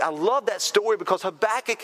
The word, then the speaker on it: Habakkuk: I mean, if you read the book of Habakkuk, and if you're wondering how do you say that, to say a back kick, and that's the easy way to say it, especially teenagers in here.